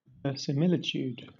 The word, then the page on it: verisimilitude: British English